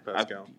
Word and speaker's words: Pascal: what's the name? Pascal? – Yeah, you said it right. – Yeah. – Pascal.